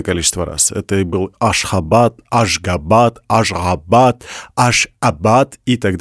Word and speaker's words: Ашхабад: Но, опять же, в зависимости от того, на каком языке вы будете говорить, будет правильно произносить на том языке, на котором вы говорите. – Будем говорить Ашхабад.